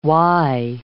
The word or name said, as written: wye